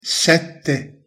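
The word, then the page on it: sette: Italian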